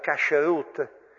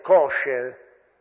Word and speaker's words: kasher: Nell'ambito della famiglia si svolge anche tutto quell'aspetto legato al cibo, quello che si chiama in ebraico l'aspetto della kasherut o kasher, o con la pronuncia ashkenazita dominante kosher, che vuol dire in realtà adatto.